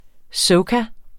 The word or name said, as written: soca